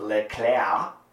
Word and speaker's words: Leclerc: Leclerc, which is how you say it, apparently, yep.